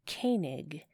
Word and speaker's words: Koenig: It's Koenig. Pronunciation of a person's name, doing that properly, is a sign of respect. – I know. – Therefore, it is Koenig.